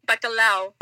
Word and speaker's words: bacalhau: How do you pronounce it in Portuguese? – Bacalhau.